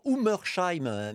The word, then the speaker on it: Ungersheim: Alors, on entend Ungersheim, mais ça s'écrit Ungersheim, U-N-G-E-R. S-H-E-I-M, je vais aller jusqu'au bout.